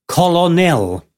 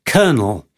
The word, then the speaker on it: colonel: It's spelt as if it should be colonel, colonel, and yet we call it colonel.